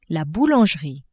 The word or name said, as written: boulangerie